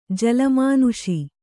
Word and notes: ಜೋಲುಮನಸ್ಸು: jōlu manassu

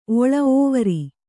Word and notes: ಓವರಿ: ōvari

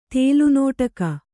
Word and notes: ತೇಲುನೋಟಕ: Kannada

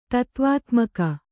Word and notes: ತತ್ವಾತ್ಮಕ: tatvātmaka

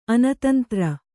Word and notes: ಅನತಂತ್ರ: Kannada

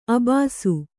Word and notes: ಅಬ್ಬೆಸ: kannada

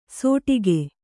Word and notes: ಸೋಟಿಗೆ: sōṭige

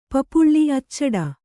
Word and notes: ಅಚ್ಚಿಡು: acciḍu

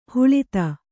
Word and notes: ಹಳತು: Kannada

